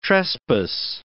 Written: trespass

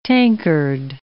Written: tankard

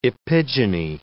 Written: epigyny

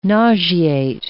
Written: nauseate

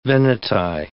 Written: Veneti

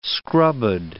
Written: scrubbed

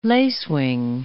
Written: lacewing

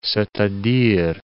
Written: c'est-a-dire

